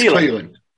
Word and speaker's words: Cleland: Sleland. It's Cleland. – Cleland. Cleland. Like Cleveland.